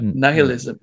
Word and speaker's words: nihilism: What's the correct pronunciation for that? Nihilism. Nihilism.